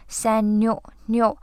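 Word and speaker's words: señorita: 我特意问了一下我的同事，他是学西语的，这个词的发音呢要注意两个点，一个是那个 N 上面它其实是有一个小帽子，对吧？所以它不是念成 N，不是念成 No，是 No New Senorita，那个二发 L 的音，所以发成 Li Senorita，发 D 的音浊化成 D 就变成了 Senorita。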